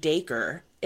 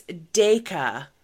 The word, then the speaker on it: Dacre: You don't pronounce his name Dacre. It's Dayka. D-A-Y-K-A-H. It was in an article and he's like, it's like Acre. But he did a pronunciation during an interview and it's pronounced Dayka.